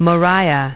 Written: Moriah

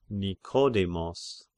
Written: Nikodemos

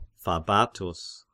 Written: Fabatus

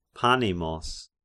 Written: Panemos

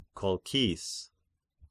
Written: Kolchis

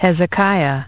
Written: Hezekiah

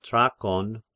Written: Trachon

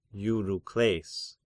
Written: Eurykles

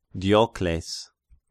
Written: Diocles

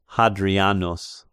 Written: Hadrianus

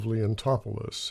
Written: Leontopolis

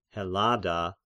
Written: Hellada

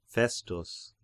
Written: Festus

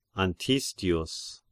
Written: Antistius

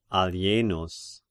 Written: Alienus

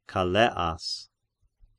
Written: Calleas

